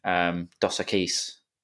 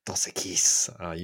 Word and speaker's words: Dos Equis: so I'm still polishing off my Dos Equis. – Dos Equis.